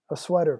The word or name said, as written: a sweater